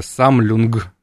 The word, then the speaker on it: Sammlung: Самлюнг. – Самлюнг.ру. – Да, коллекция как бы по-немецки. Читается Самлюнг, но ну, напишем Самлюнг.